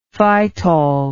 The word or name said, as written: phytol